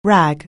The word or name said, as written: rag